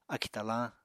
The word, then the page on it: akì talañ: Apatani